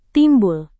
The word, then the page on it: timbul: Indonesian